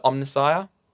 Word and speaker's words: Omnissiah: So they worship a god called the Omnissiah. Gosh, I feel like I'm saying that wrong. – No, I think it's right.